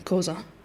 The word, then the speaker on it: Khoisan: So the interesting thing about the Khoisan languages, and I've even said that wrong, Khoisan, is it's got a series of clicks.